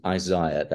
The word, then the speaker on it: Isaiah: One is Isaiah, that is the correct pronunciation of the book of Isaiah, Okay.